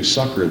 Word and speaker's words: succor: For in that he himself hath suffered being tempted, he is able to succor them that are tempted. That word succor is pronounced succor. It's not succor any more than honor is honor.